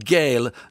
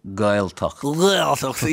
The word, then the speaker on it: Gaeltacht: Most people mispronounce the word Gaeltacht. It's not Gael nor Gwael. Listen to your guest. What is it? – Gaeltacht.